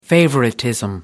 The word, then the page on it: favoritism: American English